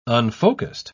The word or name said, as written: unfocused